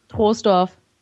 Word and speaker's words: Troisdorf: Troisdorf. – Du hast genau richtig gesagt. Aber einige sagen auch schon Troisdorf, ja.